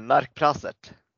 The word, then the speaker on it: Narkprasert: Kan du, hur, hur, hur uttalas det? Eh, Narkprassert.